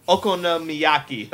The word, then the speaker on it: Okonomiyaki: I forget how to pronounce it. I know it. I've seen it in a lot of anime. What's it called? Okonomiyaki. That's what it is.